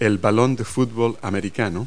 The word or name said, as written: football